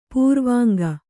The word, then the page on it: ಪಾರಿವಂಕ: Kannada